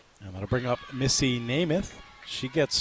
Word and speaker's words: Nemeth: They got the pronunciation guide, and it says, because it's spelled N-E-M-E-T-H, so you would think it's Nemeth, but it says parentheses, just like Joe.